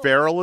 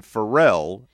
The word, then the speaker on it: Pharrell: See, everybody pronounces it two different ways. – Oh. – Farrell is one way and Pharrell is the other way.